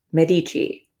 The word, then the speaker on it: Medici: Everyone in France loved her, except, of course, Francis's mother and Henri's wife, Catherine de Medici, which I only recently found out is pronounced Medici.